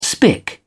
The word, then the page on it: spik: British English